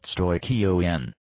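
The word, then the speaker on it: stoicheion: And as we now know, there is nothing innocent associated with Paul's use of stoichion, pronounced stoikion, in this context.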